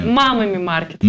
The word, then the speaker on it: Мамами Маркет: Обе они являются организаторами невероятного, опять же, невероятного проекта «Мамами Маркет». Ударение правильное? – «Мамами». «Мамами Маркет».